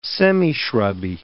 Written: semishrubby

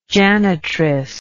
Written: janitress

